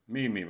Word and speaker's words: Miami: Stavas Miami men uttalas Mimi. Mimi var namnet på en grupp av stammar som var spridda över stort område i norra Indiana och sydvästra Michigan.